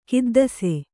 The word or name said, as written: ಖಿದ್ದಿಸೆ